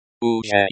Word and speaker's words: уже: The, ZH, is like a, J, in, jump, so pronounce as, уже.